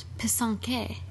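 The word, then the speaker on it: pysanky: And she mentioned that we mispronounced the technique. – Yes, I was calling it Pisanki. – Pisanki, yeah. – And it's actually called Pisanke.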